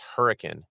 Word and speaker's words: Hurricane: However, so, if you come to Utah and you try to go to Hurricane, Utah, they will quickly tell you it's pronounced Hurricane. And they're wrong.